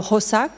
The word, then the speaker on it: Xhosa: le Xhosa, que je prononce pas du tout comme il faut, puisque la première consonne est un clic, donc c'est ce type de son prononcé par des claquements dans la gorge,